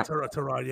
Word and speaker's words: Tyrod: Yeah, Tyrod.